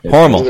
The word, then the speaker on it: Hormel: Hormel. You know, I think it's pronounced Hormel. Hormel, yeah, it just kind of kind of sits in your gut real bad, and like, oh yeah, that was a bad can.